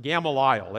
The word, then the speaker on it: Gamaliel: I am verily a man, which am a Jew, brought up in this city at the feet of Gamaliel, or if you want to pronounce it the way I pronounce it, Gamaliel.